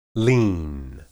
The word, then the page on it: lin: Hebrew